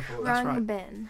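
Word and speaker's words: Khruangbin: I think it's called Krang Bin. – They pronounce it Krang Bin.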